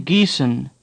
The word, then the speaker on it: Gießen: Say Gießen. – The odd letter in the middle of the word is pronounced like a sharp S.